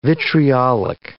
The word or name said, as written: vitriolic